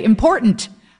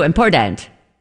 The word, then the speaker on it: important: I mean, and I just thought it was weird the way some people say important. Am I saying it wrong? – No. – She didn't put the T in it.